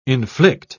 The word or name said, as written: inflict